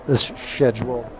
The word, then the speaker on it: schedule: Sean's not here, so I'm going to say it like the English way. Schedule. – What would he? How would he want you to say it? – Because he could. Schedule, not like schedule.